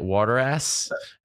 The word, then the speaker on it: Wadarass: At Waterass? How do I say it? – Yeah, it's Waterass, and it's WADARASS.